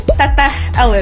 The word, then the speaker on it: Teteh Elis: What is that? How do you say it? – Teteh Elis. – Teteh Elis. – Wow. What is it? Why? – It's like a sweet nickname meaning big sister in Sundanese culture.